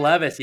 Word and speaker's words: Levis: Will Levis. Is that how you pronounce his last name? Quarterback Will Levis, yeah.